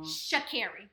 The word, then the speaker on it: Sha'Carri: Sha'Carri, remember? – Sha'Carri, yes.